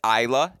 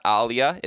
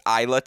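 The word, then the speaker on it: Ajla: And you're like, not only do I know this answer, I'm going to pronounce it correctly. It's Ayla, not Alia. It's Ayla Tomjanovic.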